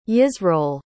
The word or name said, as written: yisroel